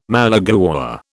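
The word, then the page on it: malagueña: British English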